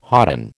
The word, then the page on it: hodden: American English